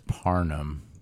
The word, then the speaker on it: Parham: Another little stream option at tight end, Donald Parham. Parham? Donald Parham? – I'm pretty sure it's Parnham. – Yeah. – It's just one of those, like, it's spelt weird, but it's easier Parham to pronounce.